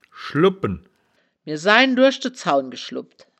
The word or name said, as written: schlubbpen